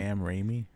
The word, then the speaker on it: Raimi: Sam Raimi.